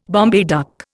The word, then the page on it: Bombay duck: Indian